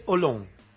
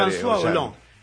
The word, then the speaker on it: Hollande: pero dígale olón. Ollanda, ¿eh? No, es una lectura muy española, muy... – Es como decir Shakespeare. – François Olón.